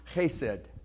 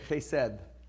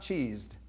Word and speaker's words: chesed: Steadfast love the, all these instances come from this Hebrew word, which is pronounced chesed. Some people say chesed, like a, almost like a DTH on the end. I, I don't uh, know biblical Hebrew uh, very well at all, so I'm just parroting the different pronunciations I've heard. It's not, it's not cheesed. I promise you that.